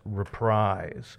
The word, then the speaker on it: Reprise: although I did mispronounce it, it's Reprise.